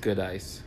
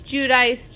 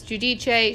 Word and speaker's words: Giudice: I thought it was pronounced Goodice. – It's Giudice, Giudice. She's changed how she pronounces it a few times.